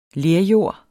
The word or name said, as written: lerjord